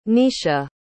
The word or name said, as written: Netia